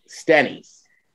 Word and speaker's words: Stenny's: And then on Sunday, as my flight was late coming out, we went over to the third ward and hung out at, it rhymes with Denny's, Stenny's.